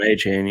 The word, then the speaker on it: Achane: They are expecting running back Devon Achane to play in this game. or A-Chain, something like that. I can't even, I'm not even sure if I'm pronouncing that right. – Devon A-Chain, yeah. – Yeah, A-Chain.